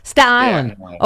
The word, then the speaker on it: Staten Island: We're going to be beating each other to death with the T's that are supposed to be pronounced in Staten Island, but are not. Staten Island.